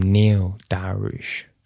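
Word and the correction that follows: Beneil: but it's Beniel, isn't it? – Beniel Daroush, need to get that right.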